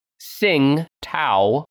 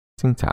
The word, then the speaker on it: Tsingtao: It's spelled T-S-I-N. G-T-A-O. Sing Tao with a T at the front. – Sing Tao.